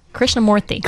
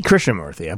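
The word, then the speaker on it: Krishnamoorthi: Krishnamurthy. Krishnamurthy.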